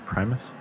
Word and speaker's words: Primus: All right, and finally, Primus with Clutch are performing at, did I say that right? Primus? – Yeah.